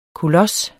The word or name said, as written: KOLOSSERNES